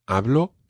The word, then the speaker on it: Hablo: Now, one thing we've got to watch with this phrase is the pronunciation of the letter B. It's not a very plosive B like B. It's more Hablo español.